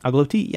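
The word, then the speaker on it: engloutie: I hope I say it right. La Quatre Dalles Agglouties. – Agglouties, yeah.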